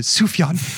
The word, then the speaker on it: Sufjan: He pronounces it Sufjan. And it was like the first time that anyone had heard his name said out loud. – Yeah. – And you could hear the whole audience abuzz with finally knowing how to say his first name.